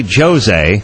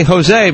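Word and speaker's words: José: Well, when I see the name Jose, I don't say Jose or Josie. I say Jose because I figured out that the J is not pronounced that way south of the border.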